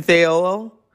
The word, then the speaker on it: Thayil: Recorded, Chris Cornell, Kim, I want to say his name right. Thank God through the power of Siri we can do it, you know. Kim Tail. Is that how you? Like I said, I want to say it right. Kim Thayil, Ben Shepherd on bass, Matt Cameron on drums, and of course the producer, Michael Beanhorn.